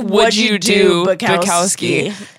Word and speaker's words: Wojciech Bukowski: They got some like rookie doing his eulogy, and he couldn't even pronounce Wojciech Bukowski. – It sounds like would you do Bukowski.